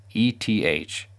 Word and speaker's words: ETH: However, there is a cost for this computation, and the cost is paid using Ethereum's native cryptocurrency called Ether, abbreviated ETH, and pronounced ETH.